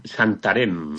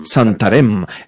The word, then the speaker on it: Santarém: Santarém. – Santarém.